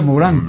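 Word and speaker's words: Morin: ¿Se escribe Morán o Morín? – Morín. – Y se pronuncia Morán, claro.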